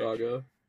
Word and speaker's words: Saga: The seven girls are charged with a quest to save the fading Saga. Oh, Sega. Prefecture. – Saga. – Are you sure? – Yeah. I mean, it's in the title. So, I mean, it's Saga. It's...